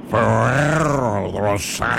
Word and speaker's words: Ferrero Rocher: And nice I said Ferrero Rocher. I know that's not how you say it, but don't tell me the correct way. – Ferrero Rocher. – Isn't it Rocher? Ferrero Rocher. Ferrero Rocher. – I thought you said it right.